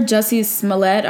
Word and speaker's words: Jussie Smollett: So we have Jussie Smollett. Is that how you pronounce it? – Um, mm-hmm. that's what I've heard. Jussie Smollett.